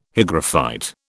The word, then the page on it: hygrophyte: British English